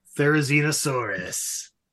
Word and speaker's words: Therizinosaurus: Therizinosaurus.